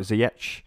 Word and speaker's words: Ziyech: So if you're looking to do two transfers and you want to free up some cash, someone like Ziyech. Uh, probably butchered the pronounce, pronunciation there. I think you, I think you did it well for uh, for Chelsea. Ziyech is another good option.